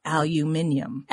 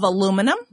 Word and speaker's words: aluminium: He says, we pronounce the metal aluminium. Aluminium. Now, he was talking about the U.K. versus U.S. pronunciation of aluminum. – Right. – And he kind of reminded me of how British people say it. It's aluminium.